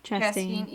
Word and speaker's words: Chastain: I don't know how to say her last name. – Chastain.